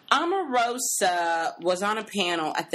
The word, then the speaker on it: Omarosa: How do you pronounce her name? – Omarosa. – Omarosa. Yes, I heard people pronouncing it Amarosa the other day.